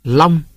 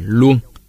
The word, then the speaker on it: Long: Long người Nam Bộ đọc là Luông.